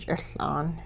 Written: ch'iL'aan

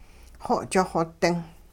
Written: xo'ch xo-ding

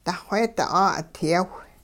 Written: duxweedi 'aa'udyaw

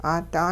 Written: 'aada

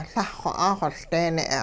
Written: Lah-xw 'axoLdeene'

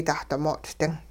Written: dahdimoot'-ding

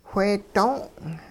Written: whee doong'

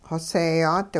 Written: xoseeyaat

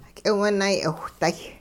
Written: k'iwunay'iwhday

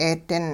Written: 'eedin

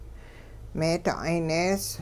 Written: meeda'ay-nees

